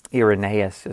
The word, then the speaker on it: Irenaeus: Irenaeus is the proper pronunciation of his name. I don't think I said that right a minute ago.